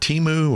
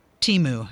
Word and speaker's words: Temu: Is it Temu or? – Temu. – The new competitor for Amazon, apparently. – They have surpassed Amazon and Walmart for a number of downloads for their app, Temu. Have you seen this? – How do you spell that? What is it? – T-E-M-U.